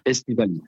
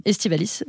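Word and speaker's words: aestivalis: il y avait de l'Aeslavelis. – Estivalis. – Voilà, Estivalis, je le prononce, et je crois qu'il y avait un peu de la brusca aussi, on avait 4 vitis.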